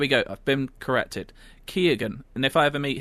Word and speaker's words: Keoghan: I heard you talking about the killing of a sacred deer on the most recent episode and pronounce Barry Schmidt, let me get the right name, Perry Keoghan's name as Kean. His surname is actually pronounced Keoghan.